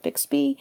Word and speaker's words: Bixby: Is that how you pronounce it? Bixby? – Yeah, and, not to be confused with the Bigsby, which is a tremolo bar on a guitar.